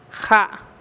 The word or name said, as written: ja’